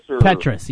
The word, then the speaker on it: Petras: Petras? – Petras, yes.